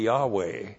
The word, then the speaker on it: Yahweh: Y-H-W-H. So holy, the Orthodox Jews never pronounced. If you pronounced it, it'd be Yahweh.